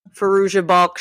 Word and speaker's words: Fairuza Balk: Fairuza Balk shirt.